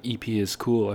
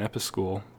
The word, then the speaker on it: EP is Cool: But, you know, I think I'm going to get into the hard stuff with a song by EP is Cool. I've been saying her name wrong. I've been saying Episcool, but I asked her, I asked her to her face, and she said it's EP is Cool so I'm inclined to believe her.